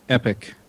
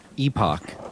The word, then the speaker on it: epoch: It's spelled epoch, but in the Unix community, it's pronounced epic. – Epic. Yeah. I think unless you're a Brit, and then I think it's still epoch.